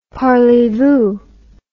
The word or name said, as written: parleyvoo